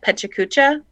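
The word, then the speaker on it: PechaKucha: I remember, gosh, I don't know how long it's been, but you had, you talked at Pechacucha. Did I say it right? – Yeah.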